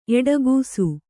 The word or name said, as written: ಎಡಗೂಸು